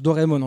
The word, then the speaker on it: Doraemon: Doraemon, hein, Doraemon, qu'on prononce Doraemon en français, n'est-ce pas C'est l'histoire d'un chat robot.